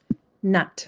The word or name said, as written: nut